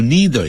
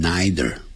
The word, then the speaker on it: neither: 记住 neither 也可以念成 neither，啊、 neither dogs nor humans can do，两个念法都可以。